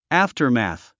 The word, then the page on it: aftermath: ǽftərmæ̀θ; アフタァマス